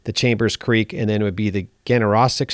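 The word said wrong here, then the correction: Ganaraska: strain. Is that how you pronounce that? – Ganarasca strain. – Yeah, it's named after the Ganarasca River over by Toronto, Ontario.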